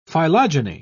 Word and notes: phylogeny: British English

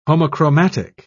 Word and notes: homochromatic: British English

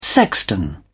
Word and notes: sexton: British English

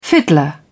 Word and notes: fiddler: British English

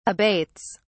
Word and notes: abates: British English

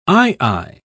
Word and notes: aye-aye: British English

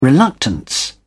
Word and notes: reluctance: British English

